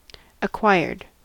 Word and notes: acquired: American English

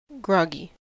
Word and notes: groggy: American English

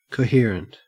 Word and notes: coherent: Australian English

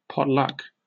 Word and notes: potluck: British English

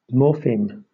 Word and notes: morpheme: British English

